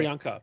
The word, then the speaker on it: Priyanka: Perica? Perenka? – This one that's going. Yeah. Look, pause here. Pause here. – Priyanka.